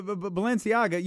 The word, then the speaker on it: Balenciaga: Was it Balenciaga? Givenchy? – Yeah. – I told you, I can't. That's the shoe. – No, but Balenciaga, you got that right.